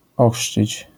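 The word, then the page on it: ochrzcić: język polski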